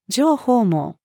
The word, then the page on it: 情報網: female